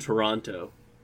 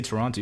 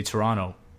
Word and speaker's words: Toronto: What do you call someone from Toronto? – Torontian. – Torontarian? – Well, they don't pronounce the last T, so it's probably Tehranian.